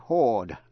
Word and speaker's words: horde: Vậy hai chữ phát âm giống nhau. H-O-A-R-D, hoard, là tích trữ. Và H-O-R-D-E, hoard, là đám hay là lũ người.